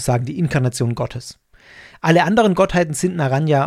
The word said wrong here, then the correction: Narayana: und Naraya, wie habe ich es gerade ganz falsch ausgesprochen? Narayana, Narayana, Entschuldigung, ähm, korrigiert mich rückwirkend auf das, was ich gerade gesagt habe.